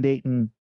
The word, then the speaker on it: Dayton: We pronounce it day, glottal stop, like we were English. – All right. – In. So Dayton, you don't hear the T.